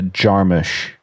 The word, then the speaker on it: Jarmusch: It's a very soft U. It's a U it's a Jarmusch.